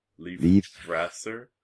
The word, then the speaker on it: Leifthrasir: But I know L-E-I-F is pronounced Leif, so Leifthrasir. T-H-R-A-S-I-R.